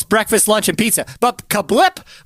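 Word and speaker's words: CABLT: It is spelled C-A-B-L-T. C-A-B-L-T, and it's pronounced Kablip.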